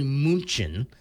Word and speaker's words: München: Like, if you go to Germany, you go to Munich, it's actually München. – Mm-hmm. – If you go to Vienna in Austria, it's Vienne, W-I-E-N.